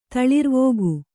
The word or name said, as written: ತಳಿರ್ವೋಗು